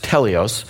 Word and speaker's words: teleos: Now, the Greek word translated perfect is pronounced teleos. And teleos